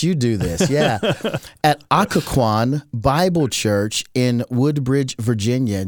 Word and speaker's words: Occoquan: Did I say that right? – Yeah, everybody misses it. Everybody, it's Occoquan. – Occoquan.